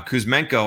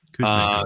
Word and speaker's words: Kuzmenko: Now Kuzmenko, I think is how you pronounce this name, is the guy that scored the goal. – Kuzmenko, um, yeah, I don't have a problem with him.